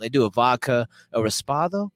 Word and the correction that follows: Reposado: I don't know. I forget what that is, but I think it's a Reposado. – Yeah. How do you say it? – Reposado.